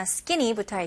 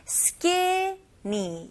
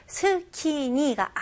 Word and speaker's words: skinny: 첫 번째 단어 skinny부터 할게요. 발음 연습해 볼게요. 여기서는 skinny, 이음절입니다. 그래서 skinny가 아니에요. 이거는 외래어고요. 영어로 확실하게 발음하실 거면 skinny, skinny.